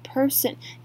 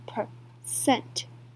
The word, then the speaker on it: percent: 那它也可以拿来指的是大气层。再我们来看是 percent 这个字呢，这个百分比虽然非常非常常用到，可是我还是非常常听到别人念成 person，也就是下面我写的人这个字 person 人呢，重音是在 p e r 的地方，就是1%的重音呢是在 c e n t 的地方，所以是 percent。